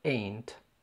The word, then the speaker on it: ain't: اولا ده نطقها كده ain't، ودي بنستخدمها للنفي.